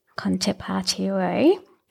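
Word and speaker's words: Con te partirò: The Italian version is, do I say in my European pronunciation, Conte Partirò by Andrea Bocelli. – Mm. – Conte Partirò.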